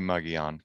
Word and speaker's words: Mogollon: But from what I've been able to figure out, there's five different correct pronunciations of the term Mogollon. So if your grandfather's uncle's cousin grew up in Sholo and he says Mogollon, that's fine. But I'm going to say Mogollon because that's how I saw some Forest Service people on YouTube say it.